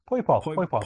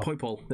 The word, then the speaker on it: Poipole: Poipole. – Poipole.